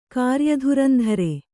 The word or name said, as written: ಕಾರ್ಯಧುರಂಧರೆ